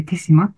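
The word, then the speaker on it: 豊島: 香川県にある手島、豊かな島と書いて手島って読むんですけども、その小さな島にある小さな美術館です。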